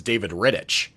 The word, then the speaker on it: Rittich: The one takeaway from this deadline was that I think I'd been pronouncing David Rittich's name wrong this whole time, because Kyle Dubas kept referring it to him as David Rittich.